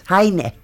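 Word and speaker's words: Heine: Nasıl okunuyor Hayne bu? – Bakayt. Bakayt. Evet. Hayne. Hayne. Hayne. – Hayne Almanca gibi sanki.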